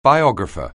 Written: biographer

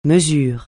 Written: mesure

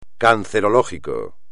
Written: cancerológico

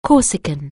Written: Corsican

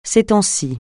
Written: ces temps-ci

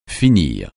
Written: finir

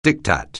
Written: diktat

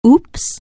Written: oops

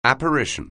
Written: apparition

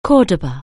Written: Cordoba

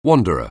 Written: wanderer

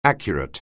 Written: accurate

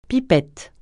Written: pipette

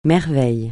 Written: merveille

